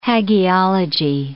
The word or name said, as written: hagiology